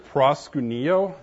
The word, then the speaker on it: proskuneo: I think it's proskuneo. Is that about right, Peter? Yeah, okay.